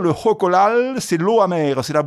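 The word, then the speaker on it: xocolatl: le nom aztèque c'est Jocolal.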